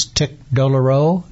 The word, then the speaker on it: tic douloureux: And as I recall from medical school, another name for this was tic douloureux.